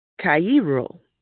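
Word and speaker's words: Cairo (χαίρω): the Greek word Cairo, which is spelled Chairo, and it means to be cheerful, calmly happy, to rejoice and be glad.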